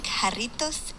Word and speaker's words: Jarritos: They have sent me a clip, which I will play now to indicate to the group the correct pronunciation of the word. – I love to drink Jarritos in Jalisco.